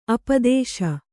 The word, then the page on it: ಅಪದಶೆ: apadaśe